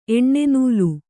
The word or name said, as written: ಎಣ್ಣೆನೂಲು